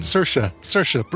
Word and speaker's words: Saoirse: Elizabeth Moss, Francis McDormand, Sacery Ronan, Christoph Waltz. Yeah, boy. – How did you pronounce Saoirse Ronan's name? – Saoirse Ronan. – Saoirse. Rhymes with inertia.